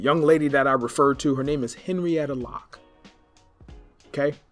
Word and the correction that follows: Henrietta Lacks: Did I say it correctly? Lacks? Henrietta Lacks.